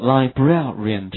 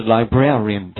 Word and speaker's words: librarian: 好， librarian 这个单词稍微它的读音和拼写有一点并不是那么的规律，叫做 librarian，其中 A 的发音是 air， R-A 的发音是 ear，所以连起来是 librarian，它表示的是图书馆的管理员，所以它的拼写就是把字母 y 去掉，后面加上 ran 就可以了， librarian 它表示图书馆的管理员。